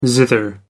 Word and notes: zither: American English

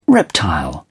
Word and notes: reptile: British English

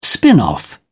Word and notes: spin off: British English

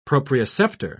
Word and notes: proprioceptor: British English

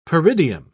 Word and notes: peridium: British English

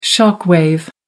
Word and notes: shock wave: American English

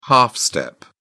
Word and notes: half step: British English

